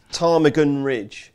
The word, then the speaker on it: Ptarmigan Ridge: I can't pronounce that. Patman Ridge and 12-1 Bar. – Ptarmigan Ridge.